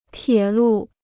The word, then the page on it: 铁路: tiě lù